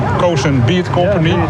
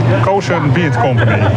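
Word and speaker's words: Cosun Beet Company: Hoe moet je het uitspreken? Suikerunie, dat is hoppa en ja, en dit is een ja, mondvol ben ik. – Cozen Beat Company.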